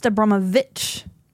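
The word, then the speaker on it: Abramović: It's spelled Abramovic, but it's pronounced Abramovic.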